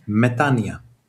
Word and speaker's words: Metanoia: Metanoia. – Metanoia. – Metanoia. – Ja. – Alltså O-et uttalas inte alls. Metanoia. – No. – O and I become I. – O och I blir I.